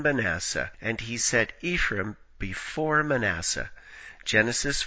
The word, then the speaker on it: Ephraim: In Hebrew, one could express double portion with the dual construction pi shanaim, which rhymes with Ephraim.